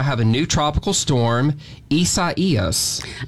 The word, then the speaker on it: Isaias: You're going to have to help me get used to saying that because it's spelled I-S-A-I-A-S. – That's right, but it's pronounced Isaias. That's the official pronunciation from the Hurricane Center, and they do the naming.